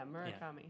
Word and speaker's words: Murakami: Yeah, Murakami. Yeah. I think it's... – Hiroki Murakami.